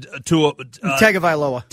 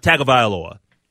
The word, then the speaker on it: Tagovailoa: Tagovailoa. – Tagovailoa, the starting quarterback for the Miami Dolphins.